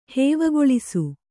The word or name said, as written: ಹೇವಗೊಳಿಸು